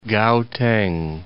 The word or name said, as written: Gauteng